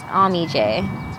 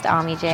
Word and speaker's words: AmeeJay: This is my chance to tell them this is exactly how it's pronounced. – Yeah, right. – And I'm like, it's AmeeJay. And they're like, just AmeeJay? Like, there's... I'm like, yep, just AmeeJay.